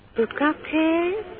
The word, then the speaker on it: Bukkake: Yeah, maybe I'm mispronouncing it. It is spelled, in English, it is spelled B-U-K-K-A-K-E. Bukake. How do you say that? – Bukake? – Yes, that's it. – Bukake, and then after that? – Palace. P-A-L-A-C-E.